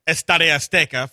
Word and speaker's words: Estadio Azteca: or Estadio Azteca for those of you who want it to be pronounced properly, and then playing a fourth game in Germany.